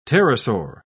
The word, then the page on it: pterosaur: British English